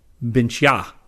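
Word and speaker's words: Bintje: Bart told me that the Dutch potatoes I was talking about in the August 2011 Science News are not pronounced Binchi, they are pronounced Binchya, A as in again.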